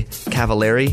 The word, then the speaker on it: Cavallari: And Kelly's also on season 2 of Very Cavallari.